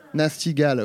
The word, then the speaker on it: Nasty Gal: Nestigal. – Nesti. Ouh, Nesti. – Ah, Nasty. – Ouais. – Nasty Gal. Oui, d'accord, ok, ça y est.